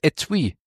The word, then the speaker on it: Etui: Es heißt Etui. Kommt aus dem Französischen.